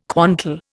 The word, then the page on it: quantal: Indian